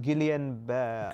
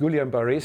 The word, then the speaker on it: Guillain-Barré: Guillain-Barré syndrome. Guillain-Barré syndrome.